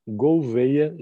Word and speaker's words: Gouveia: In Portuguese accent, it's Edgar Gouveia Jr. Thank you so much.